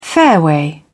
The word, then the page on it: fairway: British English